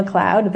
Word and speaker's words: McLeod: So my name is Deanna McLeod. That's D-E-A-N-N-A. McLeod is M-C capital L-E-O-D.